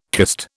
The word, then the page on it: kist: British English